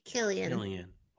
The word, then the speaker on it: Cillian: Cillian. – Wow. Okay. – The Irish pronunciation.